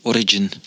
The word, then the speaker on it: Origin: Je moet die E laten vallen. Origin. Dat van origine.